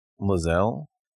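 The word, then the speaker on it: Lae'zel: But if I don't use her, I usually use Karlach, and I was using Lazelle. Is that how you say his name? – Mm-hmm.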